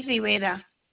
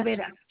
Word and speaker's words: Rivera: Sally Rivera. – Please spell that. – Rivera. Rivera. – Oh, R-I-V-E-R-A? – Yes.